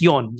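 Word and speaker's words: yon (よん): Yeah, well, yeah, 4 is basically pronounced in the same way as death in Japanese and Chinese. So, you know, instead of using shi, which literally means death, they pronounce it as yon in Japanese, Y-O-N.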